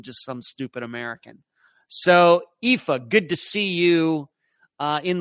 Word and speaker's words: Aoife: Aoif. Hello once again from Edmonton. It's always so enjoyable listening to you. Oh, there we go. It's pronounced Aoife. Pronounced Colleen. Aoife, Colleen. Both Irish names meaning beautiful daughter.